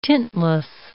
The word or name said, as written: tintless